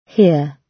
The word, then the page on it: here: British English